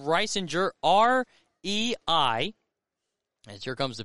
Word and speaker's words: Reisinger: But Reisinger, R-E-I, and then Singer, S-I-N-G-E-R, but pronounced Reisinger, and he will take over on the bump.